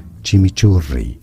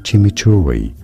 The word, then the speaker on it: chimichurri: It is said as chimichurri. Chimichurri, but that's not a silent I. – This is generally referred to as chimichurri, chimichurri. – Yeah, in all ways they pronounce the I in there.